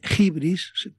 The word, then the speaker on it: Hybris: que habló del síndrome de Hibris, lo pronuncian así los profesores de griego. Hibris, escribe Hybris, que es la soberbia.